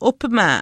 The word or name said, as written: uhpimaa